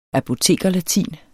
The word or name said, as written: apotekerlatin